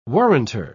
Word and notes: warranter: British English

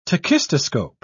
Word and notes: tachistoscope: British English

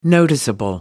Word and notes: noticeable: American English

